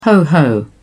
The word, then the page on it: ho ho: British English